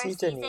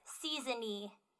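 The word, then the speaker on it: Czennie: Seasons, like, yeah, like the 4 season-y. Seasons, like spring, winter, that's how it's pronounced. Season-y.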